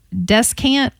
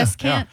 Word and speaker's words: descant: And I know I'm going to pronounce this one wrong. Descant? – No, actually, that's...